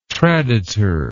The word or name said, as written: traditor